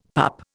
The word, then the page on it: pup: Indian